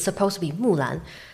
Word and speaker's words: Mulan: You have to pronounce it different. – Mulan. This is how you should pronounce it.